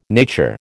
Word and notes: nature: American English

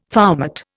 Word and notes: foumart: Indian

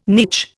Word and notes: niche: Indian

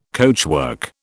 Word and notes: coachwork: British English

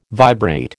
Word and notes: vibrate: American English

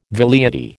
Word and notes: velleity: American English

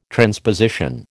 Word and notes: transposition: American English

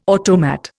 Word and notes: automat: Indian

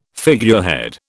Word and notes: figurehead: British English

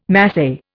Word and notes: Masai: Indian

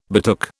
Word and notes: betook: British English

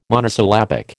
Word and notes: monosyllabic: American English

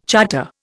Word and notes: charter: Indian

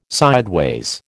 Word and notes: sideways: American English

